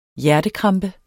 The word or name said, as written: hjertekrampe